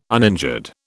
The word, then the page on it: uninjured: British English